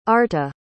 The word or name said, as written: arta